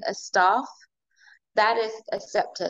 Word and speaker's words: Sceptr: So tell our audience a little bit about your story and why you started your cosmetic company, Skeptro Cosmetics. Did I pronounce it right? – It's Scepter, actually.